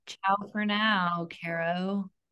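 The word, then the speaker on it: chao: They all say ciao.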